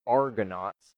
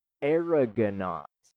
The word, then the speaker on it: Argonauts: the song is called Argonauts. But she says Aragonaut when she sings it. – Yeah. – Like arrogant. Like Aragonaut.